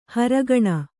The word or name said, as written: ಹೊರಗಣ